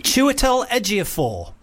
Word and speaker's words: Chiwetel Ejiofor: I've been injured all week, so, Chiwetel Ejiofor.